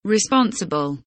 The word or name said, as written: responsible